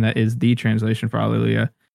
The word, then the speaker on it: alleluia: Alleluia. – I mean, like that. – We pronounce it with the H, hallelujah, but the original Hebrew word for this was alleluia, which with the H is silent.